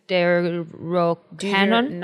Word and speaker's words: Deuterocanon: Book canon recognized by the Catholic Church, including the, wow, there's no way I can pronounce this, Deut- Deuterocanon? Yes. – Deuterocanon.